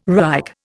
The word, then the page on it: Reich: Indian